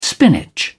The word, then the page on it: spinach: British English